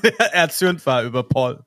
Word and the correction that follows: Pal: Paul. Mein Gott, ich kann das nicht aussprechen. – Bin ja Englishman, Paul Word. Pal, pal, pal.